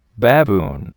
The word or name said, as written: baboon